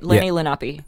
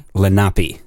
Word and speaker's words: Lenni Lenape: I think that's right. Lenni Lenape. – Lenape. – Yeah. – Is what Wikipedia says.